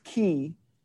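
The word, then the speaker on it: ki (כי): It's pronounced ki. It starts with a kaf.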